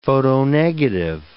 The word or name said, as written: photonegative